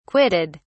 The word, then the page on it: quitted: British English